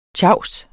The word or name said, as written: tjavser